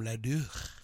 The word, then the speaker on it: Leduc: Leduc. – How do you spell that? – L-E-D-U-C. Leduc. – Leduc. How did L-E-D-U-C could become... Anyway.